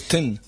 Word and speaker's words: Thin Lizzy: Pero hay gente que dice Thin, porque se escribe T-H-I-N, hay gente que lo pronuncia Thin Lizzy. – Pero es Thin. – Es Thin.